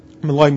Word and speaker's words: Maloi: Mali Mali. Maloi Maloi, actually, the right pronunciation.